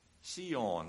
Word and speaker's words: Sion: Sion, that's the way it's pronounced in the Greek rendering, S-I-O-N instead of Z-I-O-N.